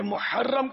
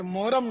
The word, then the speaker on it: محرم: یہ دوسرا جو سوال تھا اس میں تو محرم کا تلفظ ماشاءاللہ صحیح لیا، لیکن ہم عوام الناس جو ہے نا یہ محرم کو محرم بولتے ہیں، محرم آ گیا، یہ محرم نہیں ہے، محرم محرم محرم.